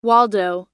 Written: Waldo

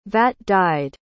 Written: vat-dyed

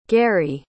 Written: garey

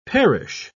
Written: perish

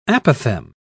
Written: apothegm

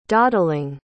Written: dawdling

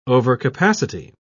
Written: overcapacity

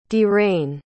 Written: dhiren

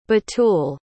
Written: batul

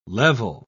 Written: level

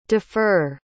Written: dufur

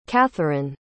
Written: catherine

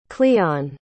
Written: cleon

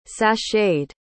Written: sacheted

